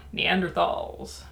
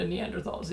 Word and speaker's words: Neanderthal: First of all, I just want to say, I'm pronouncing it Neanderthal. There is a contingent of people who believe it's pronounced Neanderthal. Both are equally valid. I just prefer the thal because there's a freaking TH in it.